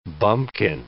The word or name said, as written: bumkin